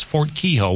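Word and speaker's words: Fort Keogh: I was like, how do you say that? It's Fort Kehoe.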